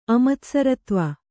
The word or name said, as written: ಅಮತ್ಸರತ್ವ